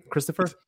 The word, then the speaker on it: Christopher: I like the three syllable what would you call it? Sort of like the cadence of my name, Christopher.